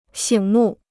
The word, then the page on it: 醒目: xǐng mù